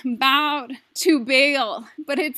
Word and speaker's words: Baal: Baal is how you say that name.